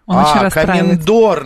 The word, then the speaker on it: комендор: Да, скажи, пожалуйста, ты когда-нибудь, вот как написал шеф-комендор, как оказывается, не комендор, он комендор. – Через «е», а ударение на «о». – Комендор, наоборот, боже мой, извините, я не дочитал.